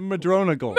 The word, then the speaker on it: Madronigal: That's how you pronounce it? – Madronigal. – Madronigal? – It looks like Madrone Eagle.